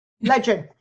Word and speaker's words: Ledyard: It's LEDYARD. – Yeah. – Pronounced like there's a J in there. – Ledyard. Yeah. – Ledyard. – Yeah. – It's like Rudyard Kipling.